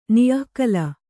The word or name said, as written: ನಿಃಕಲ